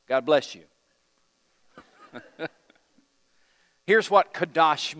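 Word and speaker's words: kadosh (קדוש): The Hebrew word for holy is the word kadosh. I know I'm using an H at the end, but that's the way you pronounce it.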